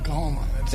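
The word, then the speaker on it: Coahoma: Did I pronounce that right? Is it Cahoma County? – Cahoma.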